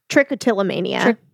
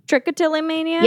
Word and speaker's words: Trichotillomania: Triotillomania is what I would say. – Trichotillomania. – Trichotillomania.